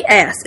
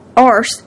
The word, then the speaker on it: ass: He once told me, say ass. It's not arse, it's ass. We say it like the rest of the world.